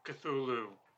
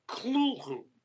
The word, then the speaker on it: Cthulhu: So most civilians pronounce it the way you do and the way I do, Cthulhu. But Lovecraft himself had the sense that it should be pronounced almost like you were trying to cough up a hairball, sort of Cthulhu. And there are people in the Lovecraft community who continue to pronounce that name as Cthulhu to this day.